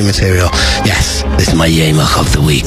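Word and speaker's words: Jemig: If you're a new listener, you're always wondering what the hell is a Yemach or Jamig, as it's spelt in the wonderful Dutch language.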